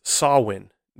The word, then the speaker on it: Samhain: Now, if you see the word, the way it's spelt, phonetically, the way it's spelt in English, you would think it'd be pronounced Samhain. It's spelled Samhain in English. But it is pronounced Samhain.